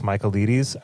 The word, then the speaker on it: Michaelides: His last name is Michaelides. Alex Michaelides, I think that's how you pronounce it. – I would say it like that.